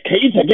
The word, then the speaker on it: Keita: This man was Mansa Musa Keita. And, you know, he was a yes, Keita gang. – Woo. Yeah, I said it right.